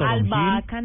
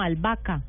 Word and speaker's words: albahaca: Albahaca, no, albahaca. – Pues, Él dijo albahaca. – Se dice, se escribe albahaca, pero se dice albahaca.